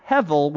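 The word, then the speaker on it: hevel (הבל): So hevel in the Hebrew, well, when you translate it from Hebrew, it's actually H-E-B-E-L, but it's pronounced hevel with a V rather than the B. I don't know. H-E-B-E-L. Yes. But then you can also write it H-E-V-E-L if you want to throw the V in there just for the pronunciation.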